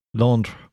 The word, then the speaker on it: Londres: If you're in the French version, it's spelled the French way and pronounced the French way, which I'm not going to try because I will get it wrong. L-O-N-D-R-E-S. – Londres.